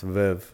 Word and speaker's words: Veuve: Well, it's pronounced Veuve, but I just say Veuve because we're in El Paso.